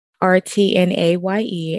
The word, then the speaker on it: Courtnaye: It's CourtnayE.